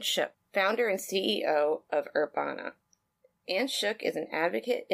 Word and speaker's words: Urbana: Yes, you are pronouncing Urbana correctly. And Urbana, I'm going to segue for a second. It's Herb Ann. That's all it is in Italian. I'm Ann. It's Herb. It's Urbana.